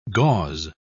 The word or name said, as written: gauze